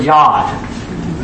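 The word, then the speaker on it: Yad (יָד): So this right here is just going to be the Hebrew word Yad. Okay? Y-A-D or Y-A-W-D. That's how you pronounce it.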